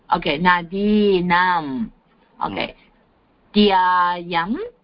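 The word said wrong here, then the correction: नद्याम्: Nadyam. – Okay, sorry. Nadyam.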